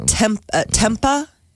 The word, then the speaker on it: tempeh: And I've never had tempeh, I think it's how it's pronounced, T-E-M-P-E-H.